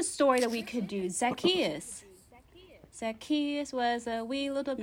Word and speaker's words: Zacchaeus: Zacchaeus. Z-A-C-C-H-A-E-U-S.